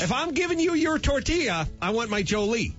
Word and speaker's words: tortilla: Someone would stop me immediately, be a white person, and go, it's pronounced tortilla. – And they pronounce it tortilla and pollo. It's tortilla.